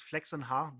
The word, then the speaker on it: Flexenha: Because my family name is Flexenha, that's how they pronounce it in Germany.